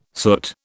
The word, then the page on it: soot: British English